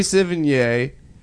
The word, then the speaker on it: Sevigny: Savini? – Yeah. How do you say it? – Chloe Savini? – No, it's not. – I said it right the first time, and now you've infected my brain with your weird...